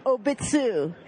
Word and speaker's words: Obitsu: Now, I have been informed it's Obitsu. Much as cute as it would be to say that it rhymes with Chobits, not quite.